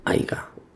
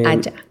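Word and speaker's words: haya: Aiga. No, no se dice aiga. – Aya.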